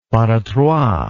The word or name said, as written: pas de trois